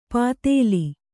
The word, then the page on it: ಪಾತೇಲಿ: pātēli; Kannada